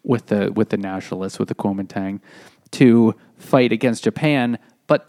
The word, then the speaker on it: Kuomintang: China's government at the time, you could say, was the Kuomintang, which is now pronounced the Kuomintang.